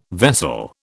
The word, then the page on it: vessel: British English